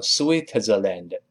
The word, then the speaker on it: Switzerland: 再比如说一个比较麻烦的词，就是瑞士这个国家，我们叫 Switzerland。当然，先拼起来，叫 SW。 I T Z E R L A N D，再来一遍，S W I T E R L A N D，跟老师读一下，Switzerland，Switzerland，注意不要读成 Switzerland，这个不行，那这是瑞士。